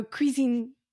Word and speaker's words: cuisine: But that's how you say it. Cuisine.